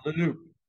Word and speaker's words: Le Loup: You pronounce the P in that? Le Loup? – Yeah, Le Loup.